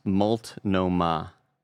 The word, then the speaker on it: Multnomah: The Portland Kevin with a $2 super chat says, LOL, pronounced just like it looks, Multnomah.